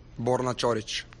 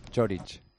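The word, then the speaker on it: Borna Ćorić: Borna Chorich. – Chorich.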